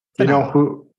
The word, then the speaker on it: Finau: Finau.